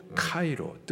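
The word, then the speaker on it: 카이로 (χαίρω): 헬라식 발음으로 카이로.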